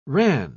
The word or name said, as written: ran